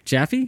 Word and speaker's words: Jaffe: How do you pronounce the name J-A-F-F-E? – Jaffe. – Jaffe? – Yeah. – That's what I thought, but that sounded wrong. – I think it's Jaffe.